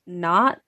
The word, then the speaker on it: Nantes: So when Leah got out of the slammer, she moved to a town named, it's spelled N-A-N-T-E-S, but when I had looked up what it's pronounced as, it sounded like not.